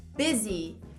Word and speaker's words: busy: Busy. Eu gosto de dar ênfase na pronúncia dessa palavra porque ela tem um U, mas esse U também não, não tem som de U. Fica B, B, busy, busy.